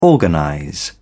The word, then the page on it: organize: British English